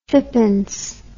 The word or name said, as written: fippence